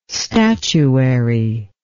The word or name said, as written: statuary